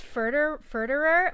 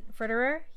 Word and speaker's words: Furterer: How do you say her last name? I can never say it. – I think it's Furterer, but I'm not sure. I'm Furterer? Yeah.